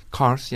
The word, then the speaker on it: Kars: And here he is wandering the streets of Kars. Is that how you say it? – Kars, yes.